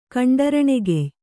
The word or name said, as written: ಕಂಡರಣೆಗೆಯ್